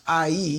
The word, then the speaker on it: Ai: And so this sets us up for now to try to take over AI, spelled AI, but pronounced IE.